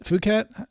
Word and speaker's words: Phuket: I just remember the traffic out of Phuket, or how do you pronounce it? Phuket. – With a P.